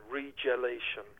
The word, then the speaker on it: regelation: So he ended up kind of doing his experiments on glaciers to promote and defend Faraday's theory of regulation, regelation, if you want to pronounce it the other way.